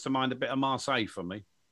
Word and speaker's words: Marseille: Don't say it like that. It's Marseille. It's Marseille, and it's the white one with the three Adidas large logos on the, coming over the shoulder.